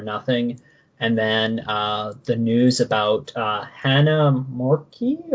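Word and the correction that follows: Hana Kimura: or my pronunciation is always the worst, but a young Japanese professional wrestler at the age of 22, from all indication, had taken her life due to a myriad of reasons stemming from a reality show. – Hannah Kimura, I believe, is the pronunciation.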